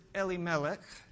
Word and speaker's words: Elimelech: During this time, there is a man named Elimelech, And if you hear me pronounce it Elimelech, it's because I've been learning my Hebrew. Eli, my God, Melech is king.